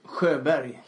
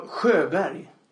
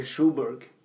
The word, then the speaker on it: Sjöberg: How do you say your last name? Schoberg? – Sjöberg. – What? – I should change it. But, no, Sjöberg. Okay. That's the Swedish pronunciation. In America, they say Schoberg. – Right. – But it's spelled S-G-O with the two dots over.